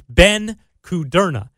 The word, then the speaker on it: Kudrna: And the play-by-play announcer told me that Ben pronounces it one way, his father pronounces it another way, and his mom pronounces it another way. But we're going to go with the way Ben pronounces it. It's Ben Kudrna.